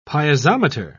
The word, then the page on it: piezometer: British English